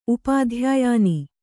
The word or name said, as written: ಉಪಾಧ್ಯಾಯಾನಿ